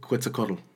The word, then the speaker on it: Quetzalcoatl: Cortez was seen as the rebirth of Quetzalcoatl.